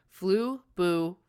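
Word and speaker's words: Flubaroo: Okay, I had to Google how to say this. Fluburu. Because, you know, I don't like to pronounce things wrong.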